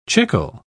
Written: chicle

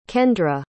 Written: kendra

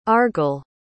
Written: argall

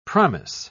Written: promise

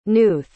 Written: knuth

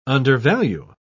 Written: undervalue

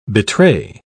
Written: betray

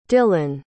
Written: dyllan

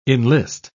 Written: enlist

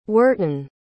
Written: hwertun

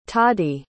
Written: tadi

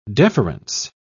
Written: deference

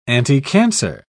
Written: anticancer

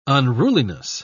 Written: unruliness

